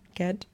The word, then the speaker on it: Gadd: Auf HBO Max, eine Serie von Richard Gadd, wieder einfach Gadd ausgesprochen, ja. – Gadd,